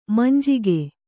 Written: ಮಂಜಿಗೆ

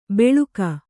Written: ಬೆಳುಕ

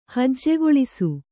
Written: ಹಜ್ಜೆಗೊಳಿಸು

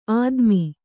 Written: ಆದಿಮ

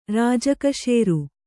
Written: ರಾಜಕಶೇರು

